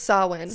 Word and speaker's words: Samhain: And then I like literally had to go online and say, how do you pronounce salmon? – And it's Samhain.